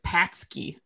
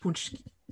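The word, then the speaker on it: pączki: So, but I've seen them in Kroger, and when you look at them, it looks like it says patsky on the label, but it's pronounced puczki.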